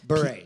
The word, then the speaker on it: Bure: Did Pavel Bure, a 2012 inductee into the Hockey Hall of Fame, play his final game? On what team did Pavel Bure? – Bure, yeah. – P-A-V-E-L. B-U-R-E, a 2012 inductee into the Hockey Hall of Fame, play his final game.